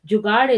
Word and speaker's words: Jugaad: At some point in their life, every Indian has experienced Jugaad.